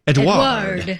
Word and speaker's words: Edouard: It's spelled E-D-O-U-A-R-D. – And it's pronounced Edward. Edward. Edward.